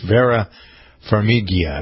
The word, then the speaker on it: Vera Farmiga: It's Vera Farmiga, I believe.